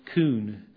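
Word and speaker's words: kun: And it's the word, it's K-U-N, pronounced coon.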